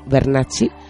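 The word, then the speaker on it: Vernacci: Bernacci, esta vez mira cómo lo pronuncio muy bien. Bernacci está escrito en italiano con V de Valeria y con doble C.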